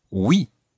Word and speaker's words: oui: So the word for yes is oui.